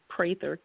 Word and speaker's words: Prather: My name is spelled with an I, Kelly Prather, K-E-L-L-I, Prather, P-R-A-T-H-E-R. Some people say Prather, but it's actually pronounced with a long A.